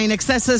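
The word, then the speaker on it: INXS: Allora, il nome della famosa band australiana In Excess si pronuncia appunto con come la parola inglese in eccesso, in excess,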